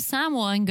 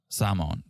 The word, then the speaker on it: Samoan: And you can learn to be a real Samoan girl. – And Kalani... – She keeps saying a real Samoa girl. – Samoan. – Samoan.